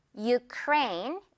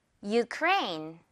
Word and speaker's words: Ukraine: 자, 마지막으로는 요건 발음 자체가 조금 많이 달라서 더 기억을 해줘야겠는데요. 우크라이나 영어 발음입니다. Ukraine 이렇게요. U 발음 기억하면서 듣고 따라해 주세요. Ukraine.